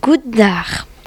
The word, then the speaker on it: goeidag: On dit « Goddag ». – Comment cela s'écrit? – G-O-E-I-D-A-G.